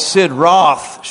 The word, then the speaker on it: Sid Roth: Now, Brian and Beth were just on the how do you say it, Sid Rop show? Or is it, oh no, it's Sid Roth show.